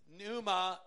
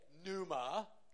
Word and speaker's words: pneuma: He says you don't say pneuma. – On říkal ne pneuma. – You say pneuma.